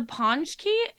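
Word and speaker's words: Pączki: This word, the Ponchki, is spelled P-A-C-Z-K-I. And then there's like a little accent under the A. – Oh, yeah. – It's not pronounced at all how I would have thought.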